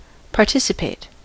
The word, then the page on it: participate: /pɑːˈtɪsɪpeɪt/